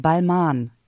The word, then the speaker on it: Balmain: Weil ich meine, das mal gehört zu haben, das schreibt sich zwar mit M-A-I-N am Ende, aber ich glaube, es wird Balmain ausgesprochen.